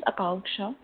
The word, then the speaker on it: Akanksha: Yeah, this is Akanksha. – I'm sorry? – I'll spell it. A-K-A-N-K-S-H-A.